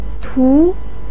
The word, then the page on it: tu2: pinyin